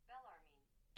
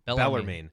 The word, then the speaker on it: Bellarmine: Let's see if you can hear it to get the pronunciation right. Bellarmine. Did you hear that? – Bellarmine.